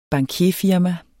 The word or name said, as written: bankierfirma